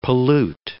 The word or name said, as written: pollute